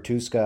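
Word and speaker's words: Tuszka: Really between Derek Tuska, or Tuska, I believe it's actually pronounced, and Jonathan Cooper.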